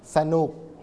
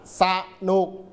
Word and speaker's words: สนุก: สนุกกนกองุ่นเราไม่พูดเราไม่อ่านว่าสนุกไม่สนุกแบบนี้ผิดต้องบอกว่าสนุกก็คืออะกึ่งเสียงเข้า